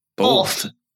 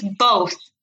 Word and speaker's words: both: You're saying it's both. – Both. Like boat. – And you're right. I say it as if it's spelled with an L, both. – Both. Yeah, that's wrong.